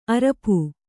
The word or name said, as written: ಅರಪು